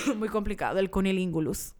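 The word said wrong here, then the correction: cunnilingus: lo dije bien. Cunilingus. cunilingus.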